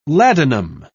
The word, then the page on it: ladanum: British English